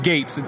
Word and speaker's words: Goats: It's spelled Goats, but I want to make sure it's pronounced Gates.